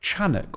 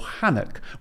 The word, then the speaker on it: Enoch: I understand it's more properly pronounced Chanuk or Hanuk, with the same Hebrew root as Chanukah or Hanukkah, the feast of dedication.